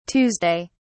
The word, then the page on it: tues: British English